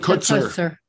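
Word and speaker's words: Kotsur: I'm wondering if he maybe knows him or has known Mr. Kushner for many, many years. I hope I'm not butchering his last name, by the way. – Right, Kutzer. – Kutzer is his Kutzer.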